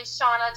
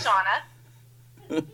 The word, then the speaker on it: Shanna: I said, first off, I'm going to correct the pronunciation of my name. It's actually Shanna Toft. It's just spelled a little bit differently, and almost everybody calls me Shanna or Shana or Shannon, but it is Shanna.